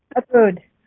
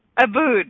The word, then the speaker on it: Ubud: Ubud. – Ubud, yeah.